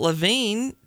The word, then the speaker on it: Lavigne: Yeah, it's I think it's Lavigne, okay. – Yeah. – That's what I thought, but it's spelled Lavigne. She turns 38. – Oh, all three letters at the end are silent.